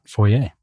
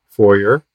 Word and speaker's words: foyer: Either pronunciation, acceptable, according to Oxford, foyer, foyer. Same spelling, though.